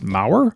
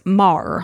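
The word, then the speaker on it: Meagher: So it's M-E-A-G-H-E-R and it's pronounced Maher? – No, it's Mar. – Mar. – Yeah, okay. But some people pronounce it Maher, I believe. There's several pronunciations, but I listen to various videos and each video pronounced it Mar.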